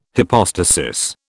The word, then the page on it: hypostasis: British English